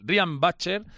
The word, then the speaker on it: Riyad Bachar: y es que Ryan, que siempre se lo lo digo mal, Riyad Bachar fue nombrado nuevo presidente de El Salvador, como ya se sabía, sustituyendo a Santi Toca.